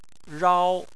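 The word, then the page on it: rao: tone 1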